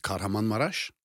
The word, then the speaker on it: Kahramanmaraş: The place where it happened is Kahramanmaraş.